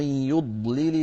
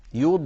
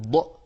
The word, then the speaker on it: يض: لو تكرمتم سيدي في الآية التي قبلها وهي 23 ومن يضلل الله، هنا ننتبه إلى الضاد الساكنة وبعدها اللام، يض ننطق الضاد مستطيلة رخوة صوتها ممطوط، يض ولا نقول ض، ليس هذا الصوت الزائد.